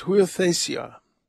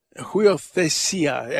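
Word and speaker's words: Huiothesia (υἱοθεσία): It's huiothesia. Huiothesia, accent on the second to last syllable. Huiothesia.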